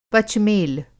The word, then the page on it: पचमेल: Hindi